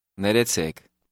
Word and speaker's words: Ներեցեք: Did you hear the rolling R? This is common in Armenian. Listen and repeat once more to practice your pronunciation. – Re. Re. Recek. Recek. Nerecek.